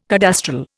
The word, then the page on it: cadastral: Indian